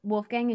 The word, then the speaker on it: Wolfgang: Wolfgang in German, yeah.